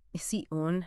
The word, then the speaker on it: ASEAN: You know, ASEAN, or ISEUN, as it's often pronounced, for anyone in business strategy, this market, well, it's not just growing fast, it's kind of rewriting the rule book.